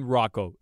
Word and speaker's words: Ruocco: Is it Ruocco? – Oh, okay. Yes. – Or is that the part you don't know? – No. Ryan Ruocco is how you pronounce his name.